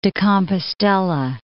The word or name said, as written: Santiago de Compostela